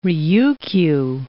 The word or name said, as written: Ryukyu Islands